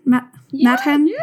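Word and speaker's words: Mädchen: I think it's Triathlon Madchen. Madchen? – Good. Very good.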